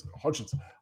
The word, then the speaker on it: Hodgins: Look, Hodgkins, he's been fantastic. Hodgins. I can't even say his name.